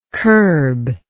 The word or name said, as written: curblike